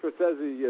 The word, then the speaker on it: Scorsese: Scorsese, actually, you know, which all my guys pronounced it wrong in the scene, which was a little funny because Scorsese says, "Hey, it's Martin Scorsese," and then everyone else calls him Scorsese.